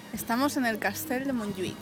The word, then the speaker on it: Montjuïc: Montjuïc.